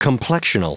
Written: complexional

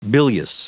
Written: bilious

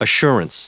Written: assurance

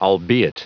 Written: albeit

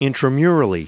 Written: intramurally

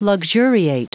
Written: luxuriate

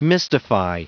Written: mystify

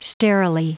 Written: sterilely

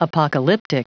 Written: apocalyptic

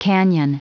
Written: canyon

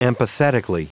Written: empathetically

